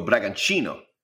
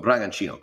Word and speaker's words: Bragantino: He goes, well, the T's in Bragantino, it's pronounced like a ch. So you go Bragantino. I go, Bragantino, great.